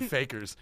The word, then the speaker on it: fakir: They pronounce it fakers.